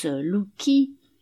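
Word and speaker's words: Wluiki: on prononce Lucchi, le W et l'I se font à peine sentir, habite encore au 12 de l'impasse Claude Bernard.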